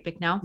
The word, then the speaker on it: Bicknell: Hopefully I pronounced that right, Bicknell.